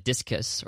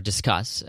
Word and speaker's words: Disqus: Then I switched over to Discus, or Discuss as it's pronounced. I finally figured out what that actually meant, but D-I-S-Q-U-S, so Discuss,